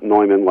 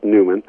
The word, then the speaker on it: Newman: It's not Neumann like I used to think. It's Newman.